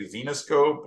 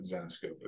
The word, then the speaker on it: Zenescope: And then everybody's like, now, now everybody's like Xenoscope or you know, they don't know how to pronounce it, but Zenescope is, hmm. is essentially the name.